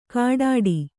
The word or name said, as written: ಖಡ್ಡಿ